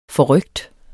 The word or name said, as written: forrykt